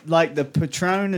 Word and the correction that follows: Patreon: is that how you pronounce alcohol? – Patreon. – I knew I was getting it wrong.